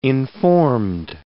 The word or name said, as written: informed